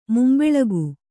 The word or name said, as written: ಮುಂಬೆಳಕು